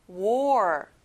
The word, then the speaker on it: war: 여기는 OR, OR 발음이 나요. 다시 따라해볼까요? war.